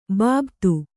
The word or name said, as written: ಬಾಬತ್